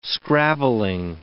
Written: scrabbling

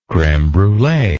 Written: creme brulee

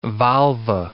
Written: volva

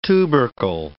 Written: tubercle

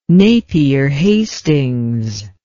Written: napier hastings

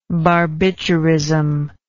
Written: barbiturism

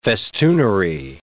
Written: festoonery

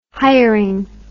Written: hiring

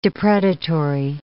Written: depredatory

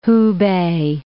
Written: Hupei

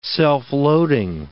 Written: self-loading

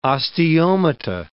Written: osteomata

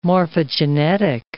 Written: morphogenetic

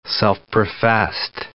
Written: self-professed

